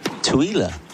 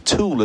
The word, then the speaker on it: Tooele: They pronounce T-O-O-E-L-E as... – Twyla. – Twyla. – Twiller. – Twiller. Well, Leslie's been in touch and says my grandson and his wife live in Twiller, and he says it's pronounced Twiller.